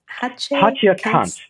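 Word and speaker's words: Hatje Cantz: How do you pronounce the name of this publisher? – Hatje Kantz. So it's a German art book publisher.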